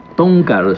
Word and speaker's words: Town Car: El Town Car, ¿así es como se pronuncia? ¿Estamos pronunciando bien? – Town Car, sí.